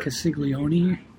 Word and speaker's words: Castiglione: There's from Kansas City, and I've got Caglione. I don't know how you pronounce it. – Castiglione.